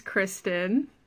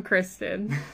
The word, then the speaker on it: Christin: My name's Kristen. Did you say your last name? Or are we just doing first names? First names. I'm Kristen. But it's spelled specially. C-H-R-I-S-T-I-N. Very different.